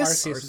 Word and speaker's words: Arceus: Arceus? Is that right? – I've always pronounced it Arceus. – Arceus is what I've heard.